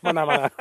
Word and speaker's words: Menominee: W-A-U. Wausau. – Wausau. – Right. – Yep. There's that one. And then there's M-E-N-O-M-I-N-E-E. – Menominee. – Menominee. – Menominee. Menominee.